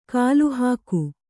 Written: ಕಲೆಹಾಕು

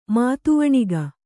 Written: ಮದವಣಿಗ

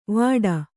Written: ವಾಡ

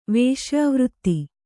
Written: ವಿಷಯವಿರತಿ

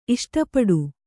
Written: ಇಷ್ಟಪಡು

